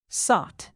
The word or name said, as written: sought